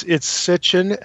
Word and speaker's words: Sitchin: It's Sitchin, S-I-T-C-H-I-N, but it's easy to say Sitchin or spell it with an E at the end, or an I.